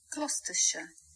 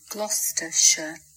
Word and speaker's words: Gloucestershire: Gloucestershire. Gloucestershire. Lollards. Lollards. Now you see that was not just the proper pronunciation, but also in the beautiful accent that makes it sound so nice.